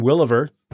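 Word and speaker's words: Willever: Williver shouldn't be that hard. It's just a compound word. Will, ever, those two words, put them together, you got my last name.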